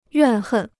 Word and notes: 怨恨: yuàn hèn

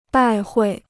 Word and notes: 拜会: bài huì

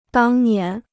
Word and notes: 当年: dàng nián

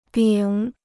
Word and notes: 丙: bǐng

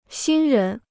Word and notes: 新人: xīn rén

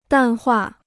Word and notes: 淡化: dàn huà

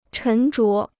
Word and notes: 沉着: chén zhuó

